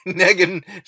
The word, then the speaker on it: Negan: And what I've learned about Negan is that just now I learned that it rhymes with Megan, which is funny because that's a girl's name. And he's super macho. Negan, like he just he just loves to write Negan.